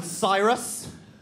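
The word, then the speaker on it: Psirus: We were called Cyrus. We were called Cyrus, guys. Cyrus! It was originally S-I-R-U-S, and then we thought that wasn't metal enough, so we added a silent P at the beginning of it.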